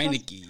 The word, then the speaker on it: Heinicke: I trust in Brady more than I do in Taylor Hickey, right? How do I say that? Heinicke. – Heinicke. – Heinicke. – I wanted to say Hickey, sorry.